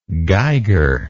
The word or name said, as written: geiger